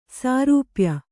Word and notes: ಸಾರೂಪ್ಯ: sārūpya

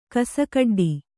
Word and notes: ಕೋಸುಗಡ್ಡೆ: kōsu gaḍḍe

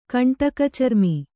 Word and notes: ಕಂಟಕಚರ್ಮಿ: kaṇṭaka carmi

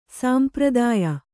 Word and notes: ಸಾಂಪ್ರದಾಯ: sāmpradāya

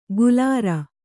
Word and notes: ಗುಲಾರ: gulāra

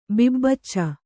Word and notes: ಬಿಬ್ಬಚ್ಚ: bibbacca

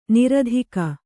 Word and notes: ನೀರ್ತೆಗೆ: nīrtege

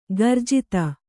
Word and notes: ಕುರುಜಾತು: kannada

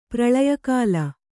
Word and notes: ಪ್ರಲಯಕಾಲ: pralaya kāla

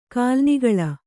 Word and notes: ಕಾಲಾನುಕೂಲ: Kannada

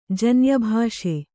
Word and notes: ಜನ್ಯಭಾಷೆ: kannada

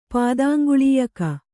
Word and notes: ಪಾದಾಂಗುಳೀಯಕ: kannada

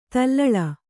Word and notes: ದಲ್ಲಾಳ: dallāḷa

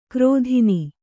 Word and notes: ಕ್ರೋಧಿನಿ: krōdhini

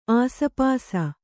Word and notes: ಆಸುಪಾಸು: āsupāsu

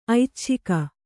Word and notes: ಐಚ್ಛಿಕ: aicchika

